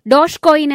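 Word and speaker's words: Dogecoin: Washington crypto കറൻസികളെ പരിഹസിക്കാനായി രംഗത്തുവന്ന crypto നാണയമാണ് ഡോഗ് കോയിൻ. ഡോഷ് കോയിൻ എന്നാണ് ഈ വാക്കിന്റെ യഥാർത്ഥ ഉച്ചാരണം.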